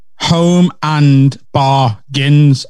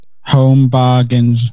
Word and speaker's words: Home Bargains: Because yours is Home and Bargains. Ours is Omen Bargain. So it's the same syllables. – There's no and in ours, dickhead, because it's called Home Bargains. – Home Bargains.